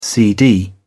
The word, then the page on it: cd: British English